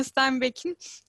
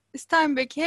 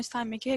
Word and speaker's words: Steinbeck: Sonrasında John Steinbeck'in İncisi'ni okudum. Bu arada Steinbeck'i hep şu Almanca ses bilgisi kurallarıyla okumak istiyorum. Steinbeck diye ama Steinbeck diye okunuyor galiba.